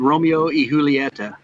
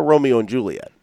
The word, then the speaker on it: Romeo y Julieta: It's Romeo y Julieta. – Yeah. – Romeo y Julieta. – Yeah. – So it's not a Romeo and Juliet.